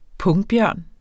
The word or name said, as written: pungbjørn